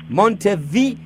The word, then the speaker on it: Montevideo: Ah, M-O-N-T-E... Devil... No me importa cómo se dice. V-V... Montevideo. Montevideo. Montevideo. Yes, like Montevideo. Like video, okay. Like video and mont. Mont. – Like M-O-N-T-E? – Exactly, yeah, video.